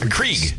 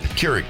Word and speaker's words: Keurig: Johnny brought in a coffee maker because Krieg. – Keurig.